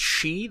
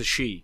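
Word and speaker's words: Shi: I think it's pronounced She, the S-H-I, a faction from San Francisco.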